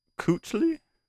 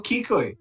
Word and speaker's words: Kuechly: So in 2019, this linebacker played for the Panthers, Luke Kuechly. – Oh no, Kuechly.